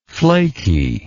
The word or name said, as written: flakiness